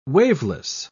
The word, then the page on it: waveless: British English